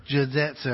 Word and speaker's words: Jiu-jitsu: Jiu-jitsu? Is that right? Huh? – Jiu-jitsu.